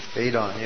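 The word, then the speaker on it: eidos: Yeah, did Plato consider those, how do you pronounce it, Aidan? – Aidan, yeah.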